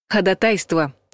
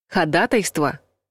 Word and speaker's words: ходатайство: Согласно орфоэпическому словарю под редакцией Аванесова, это слово из судебной лексики правильно произносить с ударением на втором слоге. Ходатайство. Рядом в словаре указано и ходатайство с пометкой «грубо неправильно», с восклицательным знаком, между прочим. Слово с ударением на третьем слоге относится к профессиональному жаргону в юридической сфере.